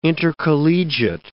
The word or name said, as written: intercollegiate